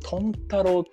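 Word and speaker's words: 豚太郎: そことか、うん、あとはあの僕が一番おすすめしてるのはあの、うん、トンタロウっていうですね、豚に太郎と書いてトンタロウっていう酒屋がありまして、何回か出てきたね、僕なりの作法でも。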